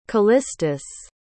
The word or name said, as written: callistus